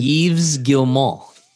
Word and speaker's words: Yves Guillemot: Yves Guillemot.